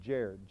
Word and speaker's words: Jair: My best friend in college's name was Yair, except in English they pronounced it Jair, J-A-I-R.